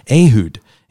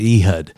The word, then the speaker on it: Ehud: Ehud in scripture, or as Americans pronounce it, Ehud, right?